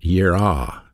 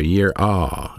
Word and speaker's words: yirah: The Hebrew word for fear is pronounced yirah. Y-E-A-R-A-W, year awe, that's the way you pronounce it.